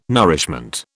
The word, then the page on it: nourishment: British English